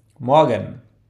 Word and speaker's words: Morgen: Morgen.